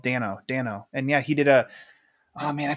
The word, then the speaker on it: Dano: And fun fact, he pronounces his last name Dano. – Paul, I'm sorry, Paul Dano.